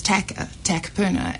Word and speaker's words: Takapuna: I in Takapuna, and so it was actually weird to say Takapuna. It was Takapuna, and it's just, you become the weird person for saying it correctly.